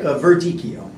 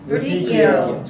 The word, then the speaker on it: Verdicchio: Yeah, well, I kind of put the little accent on there, but Verdicchio. – Verdicchio.